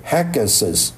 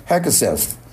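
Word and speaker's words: Hegseth: 这个主题呢其实我是一直都想讲，就是讲这个美国的总统的这个已经选上总统的这个 Donald Trump，他任命了一个44岁的美国的一个一个少校来当美国国防部长，这个人叫 Peter，叫做 Hegesus，这个字很难读，中文译成何格塞斯啊，读音也差不多 Hegesus，最后那个是 TH 啊。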